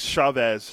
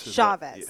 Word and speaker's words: Chavez: How do you pronounce Eric Chavez? Is it Chavez, Chavez? – That's a good question. Let me see what... – Because if Mark Kotze, his former teammate, says Chavez, you pay attention to that. – It's Chavez. – Chavez. – Chavez, yeah.